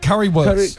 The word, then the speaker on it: Currywurst: Currywurst. – Currywurst.